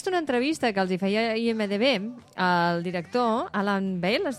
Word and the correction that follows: Ball: es diu? – Alan Ball. – Alan Ball, perdó, que eh, pronunciem malament.